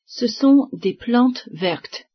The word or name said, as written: plantes